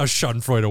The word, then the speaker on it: Schadenfreude: I don't know if I'm saying it right. Schadenfreude. – Schadenfreude is good.